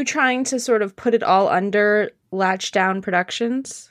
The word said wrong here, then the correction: Lockdown Productions: Yeah, it's actually pronounced Lockdown Productions.